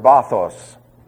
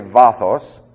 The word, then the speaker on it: vathos: The word depth is taken from the Greek word bathos, or actually vathos. That's V-A-T-H-O-S. If you prefer to pronounce the second letter of the Greek alphabet with a B sound, I'll tolerate it, but it's not correct.